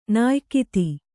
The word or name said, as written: ನಾಯಕಿತಿ